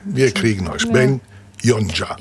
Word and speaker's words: Yonca: Y-O-N-C-A. Das ist wichtig. Ben kann man sich gut merken, aber Yonja mit Y und dann ein C. Also wenn es Ben Yonja, aber nur mit C-A ausgesprochen. – Mhm. – So, durch Zusatz geschrieben, aber ausgesprochen anders.